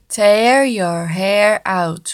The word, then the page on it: tear you hair out: slow